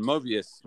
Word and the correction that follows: Morbius: must, it's Morbius or Mobius? Morbius.